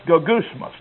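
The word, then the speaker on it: gogusmas (γογγυσμός): The word translated murmurings in our text is pronounced gogusmas.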